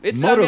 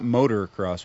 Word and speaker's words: motocross: Motocross, I'm not saying it's not cool. – Motocross seems like. Am I pronouncing that right? It's not motor cross, right?